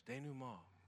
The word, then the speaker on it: denouement: But the word is pronounced denouement. It's French, denouement.